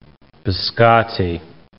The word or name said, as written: biscotti